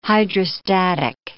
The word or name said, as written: hydrostatic